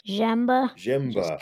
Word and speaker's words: Zemba: And your drummer in wheelchair sports camp, Greg, is it Zemba? How do you pronounce his last name? – Zemba. Kind of like blended all together.